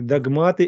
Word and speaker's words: догматы: Мы хотим, чтобы люди перешли на новый этап своего развития, на новый уровень своего бытия, чтобы поняли, что религиозные догматы – это разделение. Догматы, вернее, ударение на первое слово.